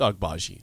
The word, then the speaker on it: Agbaji: Is it Agbaji? How do you pronounce? – It's Agbaji.